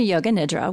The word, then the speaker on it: yoga nidra: So yoga nidra, it's supposed to be yoga nidra with an I, but it is so easy to say the yoga nidra.